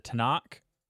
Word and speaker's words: Tanakh: It is placed between a Quran and the Tanakh. Is that how you say it? The Hebrew Bible? – I believe so.